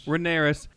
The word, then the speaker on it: Rhaenyra: Rhaenyra and Daemon kind of getting together.